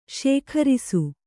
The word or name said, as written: ಶೇಖರಿಸು